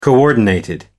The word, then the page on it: coordinated: American English